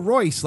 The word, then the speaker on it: Royce: And I go, Royce. She goes, spell it, R-O-Y-C-E. She goes, R-I-C-E-Y. I'm like, no, Royce. Like Ricey. – Hold on. Then I go, no, Royce, you know, like the car.